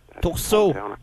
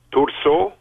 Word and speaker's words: Thurso: am I pronouncing this right, Tourso, Quebec? T-H-U-R-S-O. – Tourso. – Tourso? – Tourso. – Tourso. – Oui.